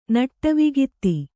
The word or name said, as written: ನಟ್ಟುವಗಾತಿ